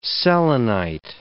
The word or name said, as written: selenite